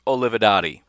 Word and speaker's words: Olivadotti: But uh, Colk, Colk, I'm trying to say his last name right, and I can't even. I called him Kolk. Kolk Olivadotti. Pretty sure that's how you pronounce his last name. Kolk Olivadotti. Goodness gracious, it's Kirk Olivadotti.